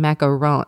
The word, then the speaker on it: macaron: The beginning of the book even explains the difference between macaroons and macarons.